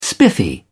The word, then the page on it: spiffy: British English